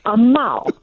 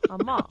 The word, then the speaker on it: Amal: No, Amal. – Amal.